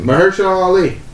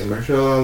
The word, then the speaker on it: Mahershala: Mahershala Ali. – Mahershala? – Yeah. – Mahershala. – Mahershala? – Mahershala. – Mahershala? – Yes. – Yeah, Mahershala Ali.